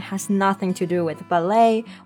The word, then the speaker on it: ballet: Her spare time is often taken up at the Harvard Ballet Company.